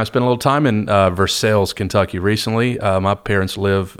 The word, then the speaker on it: Versailles: So not Versailles, Versailles, Lafayette.